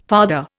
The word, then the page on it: farther: Indian